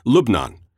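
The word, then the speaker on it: Lubnan (لبنان): "Lebanon," he said, pointedly using the Arabic pronunciation Lubnan, "is Iran's greatest foreign policy success.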